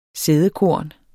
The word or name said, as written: sædekorn